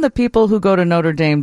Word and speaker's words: Notre Dame: We call the school in Indiana Notre Dame with a long A, rhymes with same, and we call the Paris Cathedral Notre Dame with a short A, rhymes with Dom, like Dom DeLuise.